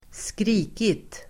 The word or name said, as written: skrikit